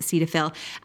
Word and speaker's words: Cetaphil: So, ever since I was a lot younger, I've used Cetaphil, some people pronounce it Cetaphil, as a cleanser.